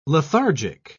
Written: lethargic

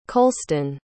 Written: colston

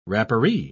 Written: rapparee

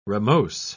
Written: ramose

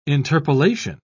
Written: interpolation

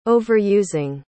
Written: overusing

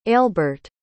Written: ailbert

